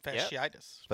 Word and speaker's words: fasciitis: He has a case of plantar fasciitis. Is that how you pronounce it? – Fasciitis. – Yep. Fasciitis.